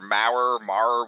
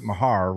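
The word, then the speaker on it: Maher: Well, okay, so remember their kicker, Mar, whatever? – Vaguely. – It's Mar, but he pronounces it Mahar, which I don't know why.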